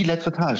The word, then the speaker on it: Lettretage: Tom, habe ich das überhaupt richtig ausgesprochen? – Die Lettretage, sehr gut.